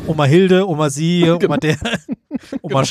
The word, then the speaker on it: Omar Sy: Mit Omar Sy in der Hauptrolle, spricht man die so aus? Weiß ich nicht. – Ja. Omar Sy.